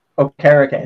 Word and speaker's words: Okereke: Okereke.